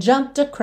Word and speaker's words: jumped: O E não é pronunciado, eu já vou do M direto pro D. Jumped across.